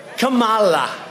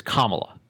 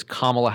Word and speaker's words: Kamala: First of all, he is intentionally mispronouncing her name. It's Kamala. She'll tell you, anyone will tell you, it is Kamala Harris, like the punctuation mark in a law.